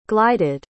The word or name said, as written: glided